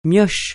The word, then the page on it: mioche: francais